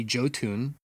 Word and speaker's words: Jotun: I had mentioned privately Jotun. I believe that's the pronunciation. – Yeah, I think you're right on that.